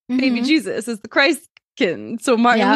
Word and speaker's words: Christkind: Martin Luther first suggested the, quote, Christkind, which is like, it looks like it's spelled Christkind, but I think it's like German, so Christkind, as the bringer of gifts.